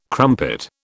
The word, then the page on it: crumpet: British English